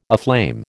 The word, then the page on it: aflame: American English